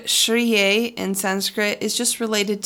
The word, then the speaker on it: Shriyei: This week's mantra is Shriye, just one word, Shriye. It's spelled S-H-R-I-Y-E-I, Shriye.